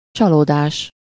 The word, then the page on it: déception: France; /de.sɛp.sjɔ̃/